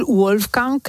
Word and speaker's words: Wolfgang: Bueno, podrías decir Wolfgang y no te diría yo que está mal. – O sea. Wolfgang, vale, perfecto. Pues señores, señores, lo ha pronunciado bien y por escrito es Wolfgang.